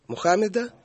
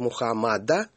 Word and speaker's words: Мухаммед: Он был прямым преемником пророка Мухаммеда. Сейчас в новых книгах пишут Мухаммада, но, в общем, привычное традиционное произношение.